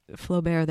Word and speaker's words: Flaubert: Can't and Won't, because Flaubert? Am I pronouncing the name correctly? – That's right. – So the pieces also similar to the dreams, Flaubert, there are pieces that run through.